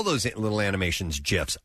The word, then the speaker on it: GIF: Jif said with a soft G is America's number one peanut butter. Gifs said with a hard G are the frequently shared looping videos that add humor, culture, and entertainment into people's daily conversations.